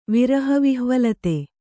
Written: ವಿರಹವಿಹ್ವಲತೆ

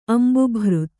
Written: ಅಂಬುಭೃತ್

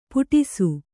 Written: ಪುಟಿಸು

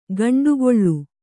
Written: ಗಂಡುಗೊಳ್ಳು

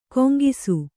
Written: ಗುಂಗಿಸು

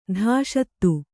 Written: ಧಾಷತ್ತು

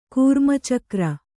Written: ಕೂರ್ಮಚಕ್ರ

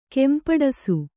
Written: ಕೆಂಪಡಸು

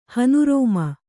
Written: ಹನುರೋಮ

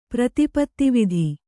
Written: ಪ್ರತಿಪತ್ತಿವಿಧಿ

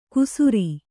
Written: ಕುಸುರಿ

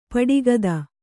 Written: ಪಿಡಿಕುತ್ತು

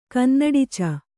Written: ಕನ್ನಡಿಚ

